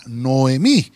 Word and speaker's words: Noemí: Voy a escribirlo, voy a escribirlo porque a veces lo, ponemos con H, pero en esta, en esta versión eh, eh, solo dice Noemí sin H y lleva el acento en la I.